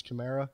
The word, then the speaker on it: Kamara: We asked him today how he pronounces his name. It's Kamara.